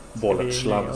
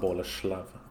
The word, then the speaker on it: Boleslav: Boleslav skulle jag säga. Nej, inte tsch, kanske, okay. Utan Boleslav, tror jag på. – Mm. – Ett SCH på den.